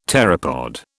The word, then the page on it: pteropod: British English